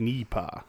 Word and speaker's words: NEIPA: It's pronounced Nipa, right? I think I saw a post. It's supposed to be ET style. – best pronounced in your ET voice. So like Nipa.